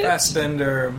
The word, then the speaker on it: Fassbender: But it also has a Michael Fassbender. Is that how you say it? – Fassbender. – Yep.